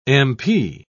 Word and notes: m.p.: British English

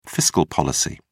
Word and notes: fiscal policy: British English

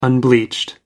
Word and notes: unbleached: American English

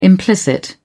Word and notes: implicit: British English

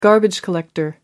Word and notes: garbage collector: American English

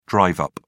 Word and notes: drive up: British English

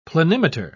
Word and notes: planimeter: British English

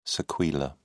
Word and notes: sequela: British English

